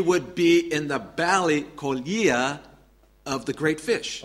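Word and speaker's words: koelia (κοιλία): Is koelia. – Wird koelia ausgesprochen.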